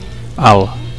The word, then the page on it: alt: Catalan